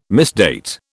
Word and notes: misdate: British English

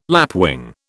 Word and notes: lapwing: British English

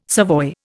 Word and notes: savoy: Indian

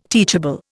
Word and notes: teachable: Indian

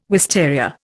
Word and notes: wisteria: Indian

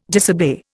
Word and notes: disobey: Indian

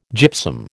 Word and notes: gypsum: American English

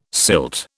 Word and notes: silt: British English

